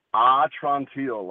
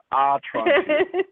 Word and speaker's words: Atrantil: So that's Atrantil, like, my belly's better. Atrantil.